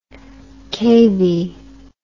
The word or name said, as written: cavie